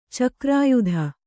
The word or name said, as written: ಚಕ್ರಾಯುಧ